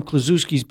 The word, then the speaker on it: Kaczynski: And I knew some Kaczynskis, but I don't know if they're related. – Kaczynski is how you pronounce it.